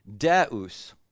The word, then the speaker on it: deus: So ubi, caritas, caritas, okay, deus. And deus is the one that folks tend to mispronounce because they pronounce it deus, right? So it's not deus, unless you're from North Carolina.